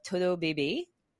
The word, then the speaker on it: Todo Baby: As CEO of Todo Baby, please pronounce that in or think of that in a Spanish accent, not French, for 10 years.